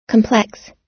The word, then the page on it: complex: American English